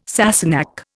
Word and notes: Sassenach: Indian

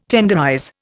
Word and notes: tenderize: Indian

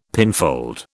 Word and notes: pinfold: British English

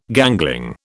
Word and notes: gangling: British English

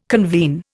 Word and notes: convene: Indian

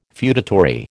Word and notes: feudatory: American English

